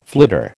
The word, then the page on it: flitter: American English